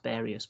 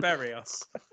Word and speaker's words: Berrios: It was more of a committee decision, and also it's not pronounced Barius, but whatever.